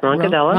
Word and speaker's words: Roncadella: Am I saying it right? Roncadella. – Yep. Roncadella.